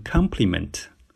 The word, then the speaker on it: compliment: 然后我们的台长，一个非常可爱的女生，就轻轻的纠正了我，告诉我她的重音应该是在 C 上面，读成 compliment。